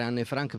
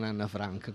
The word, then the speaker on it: Anne Frank: Tutto questo, diciamo, mi ha fatto riprendere in mano Anne Frank, che continuo a chiamare Anne Frank perché si chiama Anne Frank e non Anna Frank, come noi abbiamo ostinatamente voluto chiamarla. – Infatti è la prima persona che sento pronunciare Anne Frank invece di Anna Frank.